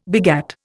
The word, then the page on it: begat: Indian